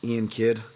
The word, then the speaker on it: Iain Kidd: Iain Kidd uh, at I A I N K I D D.